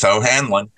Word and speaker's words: O'Hanlon: Well, they can quite simply get a hold of me on Facebook, Keith O'Hanlon.